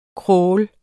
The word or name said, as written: crawl